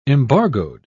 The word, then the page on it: embargoed: British English